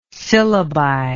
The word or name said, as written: syllabi